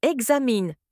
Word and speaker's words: examen: L'orthophonie, exemple, on écrit « paon » mais on prononce « pan », « pein » on écrit « examen » mais on prononce « examine », « e-g-z-a-m-e ».